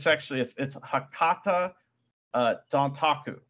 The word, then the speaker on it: Hakata Dontaku: So the first day of it's Hakata Dontaku. That's like in Japanese how you say it.